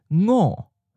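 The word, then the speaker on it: ngo (五): The literary pronunciation for five is ngo. Ngo. It ji sam su ngo. – Notice that it begins with a ng nasal sound that we don't have at the beginning of words in English, but it does end words like the word sing.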